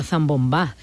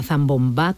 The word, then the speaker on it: zambombá: Me han dado por todos lados, a mí me da igual, porque es que la zambomba con acento en la A es lo que me inculcó mi padre, que soy muy repetitiva, y está la zambomba también.